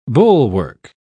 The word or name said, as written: bulwark